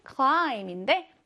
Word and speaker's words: climb: 자, 그리고 8번에는 climb인데 마지막 P는 발음하지 말자였죠.